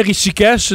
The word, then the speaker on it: Rishikesh: oui, je prononce bien Rishikesh, le nom de la ville? – Oui, c'est bien ça.